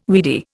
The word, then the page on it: weedy: Indian